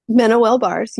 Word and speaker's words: MenoWell: Is it MenoWell bars? – MenoWell bars, yes.